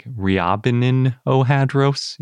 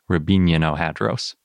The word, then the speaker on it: Riabininohadros: Its name is Rabinianohadros, and it's spelled kind of like Riabininohadros, if you see it written, in case you want to recognize it. But I looked up the Russian pronunciation, and I'm pretty sure it's more like Rabinianohadros.